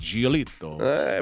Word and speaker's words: Giolito: I like the way you say that. Giolito. – I pronounce it correctly.